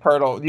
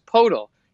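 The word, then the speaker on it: Pöltl: I thought it was Potl. Jakob Potl, the Spurs center. There's no R in there.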